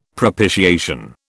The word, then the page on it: propitiation: British English